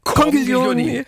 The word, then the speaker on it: Conchiglioni: Ist das richtig ausgesprochen? – Am besten ganz schnell sprechen, dann wird's klar. – Dann können wir das zusammen machen im Chor. – Conchiglioni.